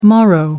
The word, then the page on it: morrow: American English